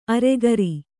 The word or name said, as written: ಅರಗುರಿ